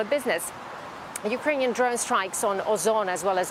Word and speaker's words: Ozon: Yulia, what's the significance of, you know, companies that are being attacked like Wildberries and now Ozon? – Yeah, that's right. So you said it right. Ozon is Russia's second largest e-commerce retailer.